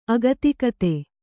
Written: ಅಗತಿಕತೆ

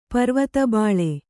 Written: ಪರ್ವತಬಾಳೆ

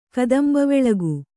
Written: ಕದಂಬವೆಳಗು

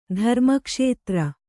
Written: ಧರ್ಮಕ್ಷೇತ್ರ

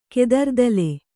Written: ಕೆದರ್ದಲೆ

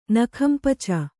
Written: ನಖಂಪಚ

